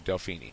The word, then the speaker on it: Delphi: The character of Delphi, which is how you... how you pronounce it, by the way, I learned.